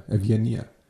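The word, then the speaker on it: Evgenia (Ευγενία): Evgenia. Was? Ja, Evgenia. – Ach so, ja. Nicht so, sondern ganz anders. – Das ist genau das Gleiche. Das ist genau das Gleiche, nur anders ausgesprochen auf Griechisch.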